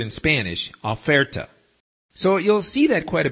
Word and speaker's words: oferta: or the word oferta, which looks and sounds almost like the English word offer, O-F-F-E-R, only in Spanish it's spelled O-F-E-R-T-A, oferta, meaning on sale.